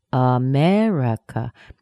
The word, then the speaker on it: America: Do you hear where the primary stress is? That's the M-E-R. Mer. But the other ones, the other vowels, are weak. America. It's not America. It's schwa, e, schwa, schwa. Those are the vowel sounds.